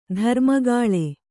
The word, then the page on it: ಧರ್ಮಗಾಳೆ: dharmagāḷe